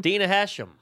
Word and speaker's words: Dina Hashem: Dina Hashem? Hashem? – Oh, Hashem? – She's a horse. – How do you say her name? – Dina Hashem.